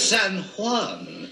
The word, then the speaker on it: San Juan: It's San Juan and San Jose. – Certainly, God. – Yes, in Spain, they always pronounce the J's as H.